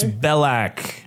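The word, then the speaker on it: Belack: How do you say it properly? – It's Belak.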